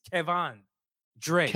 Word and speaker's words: Kevan Dre: He said, pronounce his name correctly. It's Kevon Dre.